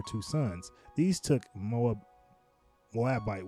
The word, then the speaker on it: Moabite: Naomi and her Moab, Moabite, Moabite daughters in I think you're saying it right, Moabite.